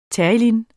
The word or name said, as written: terylene®